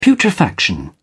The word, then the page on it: putrefaction: British English